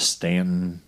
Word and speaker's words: Staunton: I think they actually pronounce it in the state of Stanton, even though there's a U in it.